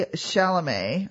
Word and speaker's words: Chalamet: So, Austin starred with Timothy Chalamet. I think that's how you say his name. – I think the T on the end is silent.